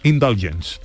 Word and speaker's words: Indulgence: ¿Cómo es indulgence? Indulgence. Indulgence. Indulgence, sí.